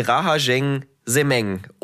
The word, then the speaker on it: Rahajeng Semeng: man Rahameng, nee, Rahajeng Semeng.